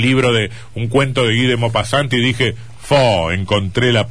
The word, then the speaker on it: Guy de Maupassant: Eh, vamos a hablar de Guy de, ¿Está bien pronunciado? Guy de Maupassant.